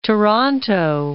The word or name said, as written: Toronto